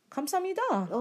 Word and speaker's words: 감사합니다: 감사합니다. – Oh, 감사합니다.